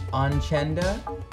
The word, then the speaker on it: Onchenda: Onchenda, that's, I'm pronouncing it correctly, right? – That's correct.